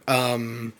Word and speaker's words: Lykotonon: You mentioned, excuse me for butchering this name, Lykotonon? Lykotonon? – Yeah, you nailed it the first time.